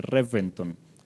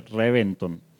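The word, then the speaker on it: Reventón: Según los desarrolladores, según los que presentan el programita este de Top Gear en la BBC de Londres, no se pronuncia, se escribe con V, pero no se pronuncia con V, o sea, no se pronuncia Reventon, se pronuncia Reventon.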